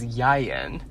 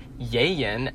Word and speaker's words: Ieuan: It is Yayen. And it is spelled I-E-U-A-N. It is a Welsh name.